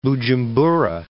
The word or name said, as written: Bujumbura